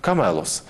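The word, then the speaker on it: камелос (κάμηλος): По-гречески слово «верблюд» произносится как «камелос».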